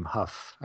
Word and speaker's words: Hough: Huff. I think that's how you pronounce his name. I believe it's Huff. H-O-U-G-H.